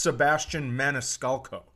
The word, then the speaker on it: Sebastian Maniscalco: uh, but this site, the only time you're gonna get a response that goes, dude, relax, is if you text Sebastian Maniscalco.